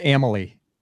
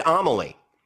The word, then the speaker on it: Amalie: They may correct you because I think it's pronounced Amalie. – No, I did. I asked that and they said Amalie.